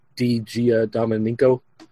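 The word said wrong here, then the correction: Di Giandomenico: Digian Domenico.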